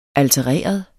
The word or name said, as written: altereret